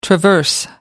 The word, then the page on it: traverse: American English